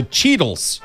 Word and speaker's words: Cheetles: So Cheetles rhymes with Beatles.